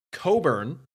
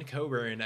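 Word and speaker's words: Cockburn: They've got Kofi Coburn is how you're supposed to pronounce it. I didn't know which of us was going to do it first. And if you knew that, did not. That's not how it's spelled. – Yep. I saved you from that one. Coburn, I believe, is the pronunciation.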